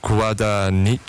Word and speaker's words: Guadagnino: Nou, daar is dus de regisseur van deze film, die heet Luca, en dan moet ik het even goed uitspreken, Guadagnino.